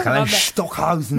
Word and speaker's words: Karlheinz Stockhausen: Avremo una puntata molto ricca oggi, infatti parleremo, adesso ho paura di dire la pronuncia, però va bene, Karlheinz Stockhausen, ho detto bene? Uno dei maggiori compositori del bene? – Detto bene? – Abbastanza, Karlheinz Stockhausen.